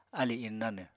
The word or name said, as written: alì innánw